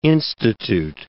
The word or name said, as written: institute